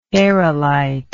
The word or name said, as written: aerolitic